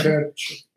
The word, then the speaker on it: Cerchi: Cerchi.